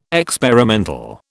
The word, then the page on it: experimental: British English